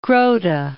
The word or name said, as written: Groote